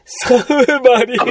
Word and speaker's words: 魚影: はい、魚影と魚の影と書いて。 – サブマリン。